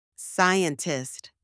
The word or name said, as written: scientist